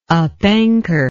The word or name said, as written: upanchor